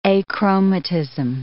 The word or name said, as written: achromatism